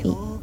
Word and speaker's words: Ae: Bueno, usualmente se escribe AE, pero se pronuncia I.